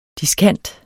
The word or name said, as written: diskant